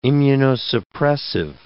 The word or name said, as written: immunosuppressive